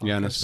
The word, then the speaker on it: Yanis: Yanis is how you say it? – Yeah, Yanis Varoufakis, former finance minister of Greece during the financial crisis. – Oh, I totally thought Yanni was the Greek pronunciation of that.